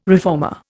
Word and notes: reformer: Indian

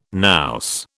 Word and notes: nous: British English

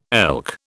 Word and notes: elk: British English

